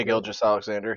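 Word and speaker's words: Gilgeous-Alexander: Gilgeous Alexander. – Shai Gilgeous Alexander.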